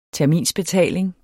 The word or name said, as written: terminsbetaling